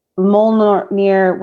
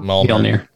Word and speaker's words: Mjolnir: People saying like if Mjolnir was gonna I said it wrong. – Mjolnir.